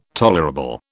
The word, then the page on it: tolerable: British English